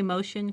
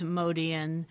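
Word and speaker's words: Motian: Well, not really, because it's Paul used it from about the mid-70s on as a pronunciation of his name. But but growing up, and technically it is Modian, and and if you listen to some, there's some old live recordings where he gets introduced and they say Modian.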